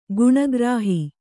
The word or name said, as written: ಗುಣಗ್ರಾಹಿ